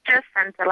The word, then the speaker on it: Jacinta: Jacinta, like I just sent it to the store.